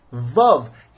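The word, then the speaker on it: Vav (וו): And when you pronounce the word Vav, it's Vav, as if it were a Vav with a chametz under it and another Vav.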